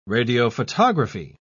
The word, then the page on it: radiophotography: British English